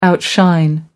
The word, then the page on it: outshine: American English